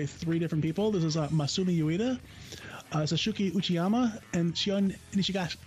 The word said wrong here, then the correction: Nishigaki: I butchered that one. Shion Nishigaki.